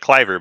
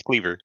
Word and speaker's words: Cleaver: Yeah, it looks like Cliver, but it's Cleaver.